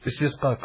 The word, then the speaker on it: Istisqa: So once again, yesterday, Alhamdulillah, when we performed the Salatul Istisqa, am I saying it right? – Istisqa, correct.